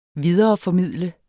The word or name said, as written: videreformidle